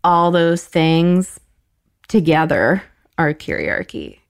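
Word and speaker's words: kyriarchy: It's K-Y-R, curiarchy. It's like. Just a curiarchy? – Word.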